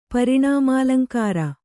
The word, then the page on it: ಪರಿಣಾಮಾಲಂಕಾರ: Kannada